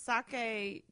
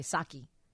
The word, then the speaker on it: Saki: Oh, no, but this is sake. Japanese sake. Stuff is with an E. This is an I. – Okay, Saki. Am I saying it right now? – Yes.